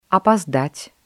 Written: опоздать

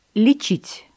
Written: лечить

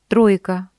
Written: тройка

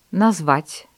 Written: назвать

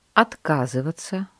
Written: отказываться

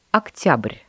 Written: октябрь

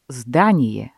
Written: здание

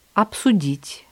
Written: обсудить

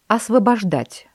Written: освобождать

